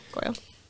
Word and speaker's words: Goyle: Gregory Goyle? – Goyle? – Yeah. – That's how you say it? – Yeah, Goyle.